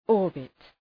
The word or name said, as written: orbit